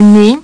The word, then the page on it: nez: French